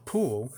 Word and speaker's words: pull: 在这边你在念的时候，因为 pulls pulls，其实在念 p u l l pull pull，其实后面你的舌头会做一个小小的了了，因为 l 了它的音标里面发音就是了，所以 pull。